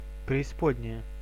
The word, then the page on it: преисподняя: /prʲɪːsˈpodʲnʲɪjə/; preispodnjaja